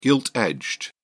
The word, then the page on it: gilt-edged: British English